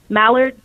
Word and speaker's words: Mallard: It takes place in a small Louisiana town called Mallard. Am I saying it right? – Mm-hmm. – mallard, like mallard duck.